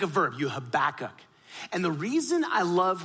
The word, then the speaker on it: Habakkuk: And to do that, what I'd love for us to look at is the prophet Habakkuk. Now you got to say his name with some phlegm, Habakkuk, right? Because he's Hebrew, right?